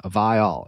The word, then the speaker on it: Vile: And I always remember as a kid being like, how hard is it to, it's how it's Vile. It's how it's spelled.